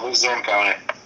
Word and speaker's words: Luzerne: And, you attended, I might pronounce this wrong, is it Luzerne County Community College? – Yep. Luzerne County.